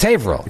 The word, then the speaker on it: Haverhill: There's the other issue here is it's spelled Haverhill. – Yes. – Oh, people from Boston don't actually pronounce all the syllables. – Can I tell you what a reasonable person like Ellie's... – It's spelled Haverhill? – Yes, but no one says that. It's Haverhill.